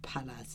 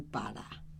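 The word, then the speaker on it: para: 他的像那个不是念帕拉，是念巴拉，他都念不对。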